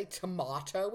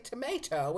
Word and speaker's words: tomato: We don't say tomato, we say tomato, which rhymes with potato.